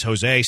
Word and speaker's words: Jose: Jose sounds like an H, but it's spelled with a J.